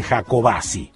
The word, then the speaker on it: Jacobacci: Tengo siempre la pronunciación Jacobacci, pero bueno, qué sé yo, no sé. Ellos, los de ahí, que son los que más derecho tienen, dicen Jacobacci. Y punto.